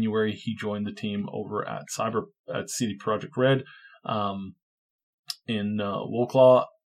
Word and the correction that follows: Wrocław: Well, sorry, how do you pronounce that city? – Wrocław. – Yeah. – Yes. – Wrocław.